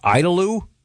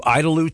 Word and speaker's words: Idalou: Maybe it's pronounced Idaloo. IDALOU, Idaloo, Texas.